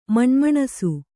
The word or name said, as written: ಮಣಮಣಿಸು